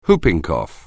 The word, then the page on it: whooping cough: anglais